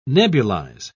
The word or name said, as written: nebulize